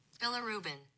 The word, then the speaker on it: bilirubin: Billa Rubin. – Billa Rubin. Yeah. – Oh, I said Billy Rubin. It's Billa Rubin.